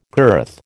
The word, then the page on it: girth: American English